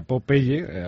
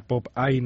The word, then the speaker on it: Popeye: Sí, mañana nos han llamado para poner, digamos, el broche a la gala de entrega de los premios Popeye, que se pronuncia Popeye, al contrario de que la gente piensa Popeye, ¿no? En inglés, Popeye.